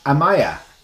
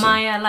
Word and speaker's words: Amaya: It is friend of the show, Dr. Manuela Amaya. – Yeah, thank you. – How did my pronunciation go? – That was perfect, like you said before. Awesome. Amaya like papaya.